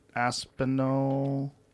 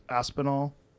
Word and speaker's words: Aspinall: I think it's pronounced Aspinall. He's an English gentleman.